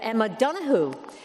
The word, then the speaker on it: Emma Donoghue: So let's just remind ourselves, though, because we know we have huge fans of the authors here, but we have Emma Donoghue. There are many in this room who understand that's the proper pronunciation of her name.